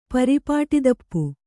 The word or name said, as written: ಪರಿಪಾಟಿದಪ್ಪು